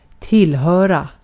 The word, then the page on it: belong: [bɪ.ˈlɔŋ]